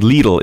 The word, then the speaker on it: Lidl: You mentioned one of your former employees earlier in the interview, Lidl, or what we have been mispronouncing as Lidl. How do you pronounce it? – We say it Lidl in German.